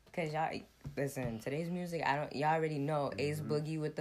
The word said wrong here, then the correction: A Boogie: the Hoodie, is that how you say his name? – A Boogie with the Hoodie.